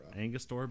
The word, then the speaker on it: Angostura: Just get yourself some Canadian whiskey, some sweet red vermouth, and then add in some Astora bitters. Angostura bitters. I am sorry. I mispronounce that thing so often.